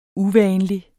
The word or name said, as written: uvanlig